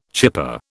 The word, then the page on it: chypre: British English